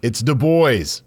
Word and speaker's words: Du Bois: And someone laughed at me and said, we're not that fancy here. It's Du Bois.